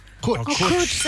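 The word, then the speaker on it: cwtch: It's called Kutch. – Kutch.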